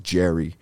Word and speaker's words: Gerry: I think they pronounce it Jerry. G-E-R-R-Y, Jerry Polisi or something like that. Polici, Polisi, G-E-R-R-Y-P-O-L-C-I.